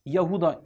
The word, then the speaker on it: Yahuda: O 12 oğlundan bir tanesi Yahuda ismindeydi. Yahuda diye de okunuyor, Yahuda diye de okunuyor. İşte o Yakup Aleyhisselam'ın 12 oğlundan birisi olan Yahuza'nın ya da Yahuda'nın soyundan geliyor Davut Aleyhisselam.